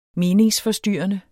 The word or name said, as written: meningsforstyrrende